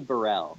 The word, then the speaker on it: Brhel: It's actually Brhel.